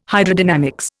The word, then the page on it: Hydrodynamics: Indian